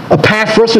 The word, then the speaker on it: Epaphras: Number six, Epaphras is the way we'll pronounce it.